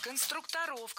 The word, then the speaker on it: конструкторов: Вот именно конструкторская команда, навскидку, это сколько было человек, вот отряд конструкторов? – Конструкторов, конструктора, это неверно. В слове «конструктор», опять же, ударение не меняется. Всегда сохраняется на «у» во втором слоге. Конструкторы, конструкторов, конструктором.